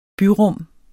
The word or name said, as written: byrum